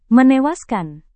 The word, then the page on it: menewaskan: Indonesian